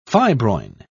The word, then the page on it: fibroin: British English